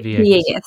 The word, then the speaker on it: Villegas: Villegas.